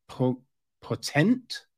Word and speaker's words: Potente: So this is written and directed by Franca Potente. I feel that's how you say it. P-O-T-E-N-T-E. Yeah, let's say Franca Potente.